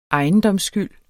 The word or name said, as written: ejendomsskyld